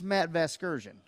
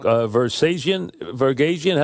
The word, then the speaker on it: Vasgersian: But here's how you say his name. It's Matt Vasgersian. Just like that.